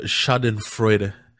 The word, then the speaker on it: Schadenfreude: We don't get the Schadenfreude, however you pronounce that. – You pronounced it pretty well.